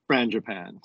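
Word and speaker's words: frangipane: But I see we have a pronunciation guide on this. – And it says Frangipan. – Frangipan.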